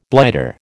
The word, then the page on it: blighter: American English